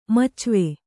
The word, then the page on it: ಮಚ್ವೆ: kannada